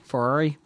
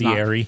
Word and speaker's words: Fieri: Ferrari? How do you say his name? – It's Fieri.